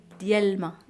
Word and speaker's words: l'ma: Relay. – D'l'ma. – D'l'ma. – Ouais, relay d'l'ma. Comme je la prononce vite, tu n'entends pas d'l. On dit relay d'l'ma. D'Yelma ou Relay d'Elma pour aller trop vite.